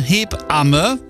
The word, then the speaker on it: Hebamme: Und ja, wir müssen uns beim Aussprechen von Hebamme richtig Zeit lassen in der Mitte. Das heißt ja nicht Hebamme, sondern Hebamme.